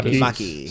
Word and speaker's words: Maki: Makey, Maki, how do you say it? – Maki.